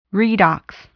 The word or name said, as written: redox